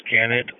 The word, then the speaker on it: Gannett: It's Gannett Alley. G-A-N-N-E-T.